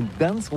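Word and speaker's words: Gdańsk: Ça s'écrit G-D-A-N-S-K. On dit Gdansk ou Gdansk, on prononce le G ou pas?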